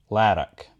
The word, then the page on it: Lerrick: Shaetlan